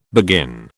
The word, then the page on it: begin: British English